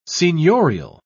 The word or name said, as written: seignorial